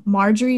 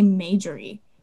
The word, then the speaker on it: Marjorie: Oh, Marjorie, is that how you say it? – Yeah. – Every single time I've tried to say her name, I say Majorie. there's an R in the name.